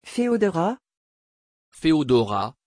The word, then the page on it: pheodora: French